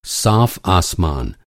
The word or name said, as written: आसमान